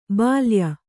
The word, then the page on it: ಬಾಲೇಯ: bālēya